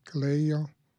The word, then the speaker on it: colegio: So when you say colegio, am I saying that correctly? Colegio? – Colegio, mm-hmm.